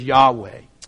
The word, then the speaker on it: Yahweh: We pronounce that with some syllables in there as Yahweh. Whenever you look through your Bibles and you see the word Lord, L-O-R-D, capitalized, so it's capital L, capital O, capital R, capital D, it's because the word underneath it was the word Yahweh.